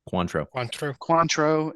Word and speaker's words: Cointreau: Contrier. – Cointreau and lime juice.